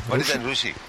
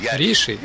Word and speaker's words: Риши: Руши? Что значит Руши? Риши? А, понятно. Вы говорите Риши, мы говорим Руши. Разница в произношении.